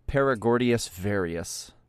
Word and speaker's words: Paragordius varius: By the way, Eric, the pronunciation of that is pari. Paragordius varius.